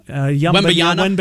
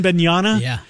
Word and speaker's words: Wembanyama: Wembanyama. – Wembanyama.